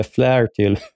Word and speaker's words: Flaherty: Men Wall Street Journal hade skrivit om det här, så det som Peter Flaherty, eller Flaherty som man uttalar det, stod och sa där i talarstolen, det var ju redan ute.